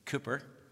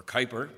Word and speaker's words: Cowper: It was written by William Cooper, or Cowper, whatever way you want to pronounce it.